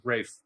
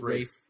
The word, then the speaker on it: Ralph: You know, this is definitely the weaker storyline B, probably something they have to give Ralph Fiennes, or how do you pronounce his name? – Rafe. – Or, it's Rafe. Rafe Fiennes.